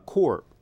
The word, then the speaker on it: corps: If you take the French-derived word corps, for Marine Corps, C-O-R-P-S, we pronounce it without the S, that's a body of Marines.